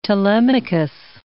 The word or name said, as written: Telemachus